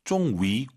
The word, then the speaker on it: 终于: 终于, 위 하지 마시고 와유 발음, 입술 동그랗게 말아서 终于 이렇게 발음하시기 바랍니다.